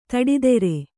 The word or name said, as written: ತೋಟದೂರ